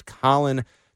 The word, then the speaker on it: Khalen: It's spelled K-H-A-L-E-N, but his name is Colin Saunders.